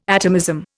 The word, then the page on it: atomism: Indian